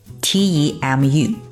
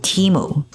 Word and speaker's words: Temu: 嗯哼，怎么读呢？就是 T E M U Temu。